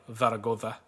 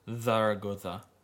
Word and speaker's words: Zaragoza: I would also like to point out at this point in time that if we're pronouncing it correctly in Spanish, it would actually be Real Zaragoza. – Zaragoza. – Yeah. The Z gets lisp just like the S's do in Spain Spanish.